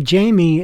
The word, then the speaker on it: Jayme: Before Jamie, and by the way, Jamie is spelled J-A-Y-M-E.